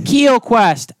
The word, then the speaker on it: Kuehlkuest: It's pronounced Keel Quest at KUEHLKUEST. – See, it's like Quest, but with KU.